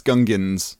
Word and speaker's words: Gungan: Is it the Gungas? – No, it's Gungans.